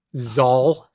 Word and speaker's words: Zal: Zal, spelled like Paul, but with a Z.